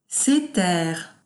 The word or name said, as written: ces terres